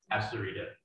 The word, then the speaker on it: Astarita: Asfrida. – Okay. – You might have to spell that for Jennifer. – Okay. So A-S-T-A-R-I-T-A.